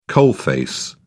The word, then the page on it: coalface: British English